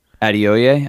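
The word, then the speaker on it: Adeoye: And so what happened is one of the guys on the podcast, Blessing, I don't know how to pronounce his last name. – Adioye. – Adioye? Mm-hmm.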